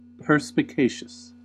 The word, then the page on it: perspicacious: American English